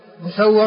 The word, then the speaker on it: مسور: مسور ونسور، مسور بضم الميم وفتح السين والواو المشددة، ونسور بالميم المكسورة والسين الساكنة والواو المفتوحة.